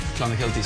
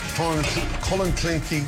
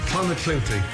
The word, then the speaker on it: Clonakilty: Irish tea bags are Clonakilty sausages from Cork. – Clonakilty. – Clonakilty. – Thank you.